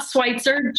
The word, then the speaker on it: Switzer: It's not Switzer, just like Switzerland.